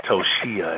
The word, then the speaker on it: Toshia: And I'm scared to say her name because I keep saying it wrong. – Toshia. – It's Toshia. It sounds so pretty the way she says it rolls off her mouth.